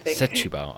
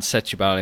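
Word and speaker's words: Setúbal: You came, 13th in, Sabutu. – I think it's Setubal. – I can never pronounce that. Well, you placed 13th in Setubal in Portugal, which it's actually a very historic venue and a tough swim as well, looking at the conditions.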